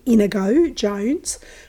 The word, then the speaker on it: Inigo Jones: That being said, if any of you are interested, go to Inigo Jones, and that's pronounced correctly, Inigo Jones.